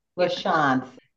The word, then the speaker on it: LaChonce: So welcome to our podcast, Jennifer LaChonce. Ms. LaChonce, or maybe we say Jennifer, please. – I love the last name, though. Say your last name again, Jennifer. – LaChonce. – LaChonce.